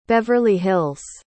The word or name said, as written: Beverly Hills